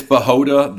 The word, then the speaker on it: Bachota: It's Bachota. The C is silent.